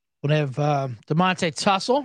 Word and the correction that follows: Tuggle: right? Running back from Ohio. Mm-hmm. – Tuggle, right? – Tuggle. – Tussle, yep. Tuggle. I tussle with my pronunciations a lot and reading my handwriting.